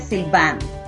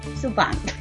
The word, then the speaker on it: Sylvain: Encontré que se dice Sylvain, algo así, pero es sin la... – Sylvain.